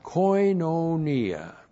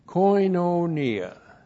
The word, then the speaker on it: koinonia: And then we have koinonia. Koinonia. Looks like that in the Greek. K-O-I-N. Long O-N-I-A. Koinonia.